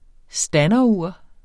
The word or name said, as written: standerur